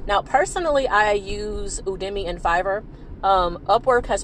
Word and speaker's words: Udemy: It's spelled U D E M Y dot com, Udemy.